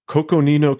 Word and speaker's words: Coconino: It's Coconino County, I believe, is how it's pronounced.